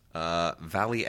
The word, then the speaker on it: Valie: Valley Export. That's spelled V-A-L-I-E. Not like she's being exported from the Valley, but that's actually a Germanic name, I guess.